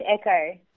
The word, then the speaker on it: Ecoh: Is that how you pronounce it? Ecoh? – That's correct, Echo.